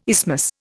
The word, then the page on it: isthmus: Indian